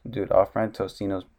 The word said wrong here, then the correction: Totino's: no, Totino's. Remember they had that ad where they kept telling people how to say it? – God damn it.